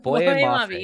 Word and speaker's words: Boye Mafe: How do you pronounce his name? Because I would say Boye Mafe, I believe. Boye Mafe.